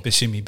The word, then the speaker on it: Buscemi: Buscemi.